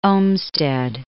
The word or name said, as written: Olmsted